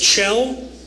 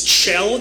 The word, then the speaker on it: Kjell: My name is Chell. It's Chell. It's Norwegian. Spelled K-J-E-L-L, pronounced Chell.